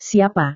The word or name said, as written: siapa